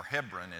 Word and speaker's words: Hebron: David will be in Hebron, or Hebron as it's pronounced today in Israel.